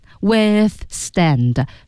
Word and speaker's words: withstand: withstand， ths 要从 th 滑到 s， ths 还得滑过去，是吧？ – withstand。